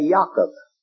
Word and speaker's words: Yaakov: We would say Jacob, The Jew would say Yaakov.